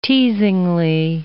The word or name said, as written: teasingly